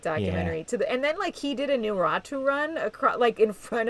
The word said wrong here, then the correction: Naruto: He did a what? Oh, a Naruto run? – Yeah, Naruto. How do you say it?